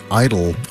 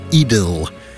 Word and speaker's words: idyll: The musical form known as an idyll, I-D-Y-L-L, means something idyllic, peaceful, or picturesque, like a beautiful, quiet meadow. Of course, when you say idyll, a lot of us here in America think you're saying the word I-D-O-L. The Brits get around this problem by pronouncing the word idyll, so there's no confusion.